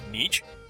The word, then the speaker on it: Nietzsche: You've managed to answer a few questions I've had, one for well over 10 years, namely, how does one correctly pronounce Nietzsche? The answer is Nietzsche. The G is silent, as in knife.